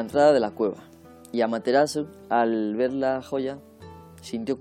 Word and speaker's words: Amaterasu: Bueno, ya sabéis que se pronuncia casi con toda seguridad Amaterasu.